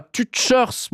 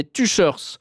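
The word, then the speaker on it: Tüscherz: Et puis j'ai fait une info routière un peu chaotique où j'ai mal prononcé le nom d'un endroit près de Bienne, et un auditeur me dit que Tuchers, ça se prononce pas Tuchers, mais Tuchers. Voilà, il me dit que c'est Daucher en français, mais que personne ne dit ça.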